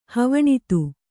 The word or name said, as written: ಹವಣಿತ್ತು